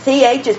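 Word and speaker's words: Chi (חי): The CH is pronounced like Bach, so Chi. And then the I, like in high, so Chi.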